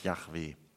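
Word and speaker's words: Jahwe: Aber eigentlich ist wahrscheinlich die richtige Aussprache dieses Namens Yahweh.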